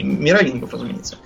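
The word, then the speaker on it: Меровингов: И вот с этого Хлодвига пошла династия э, мировингов. – А, они же мировингов. Мировингов, да. да. Я, я все время вот эти вот дурацкие римские, извините, варварские имена все время путаю ударение. Мировингов, разумеется. Каролингов, мировингов.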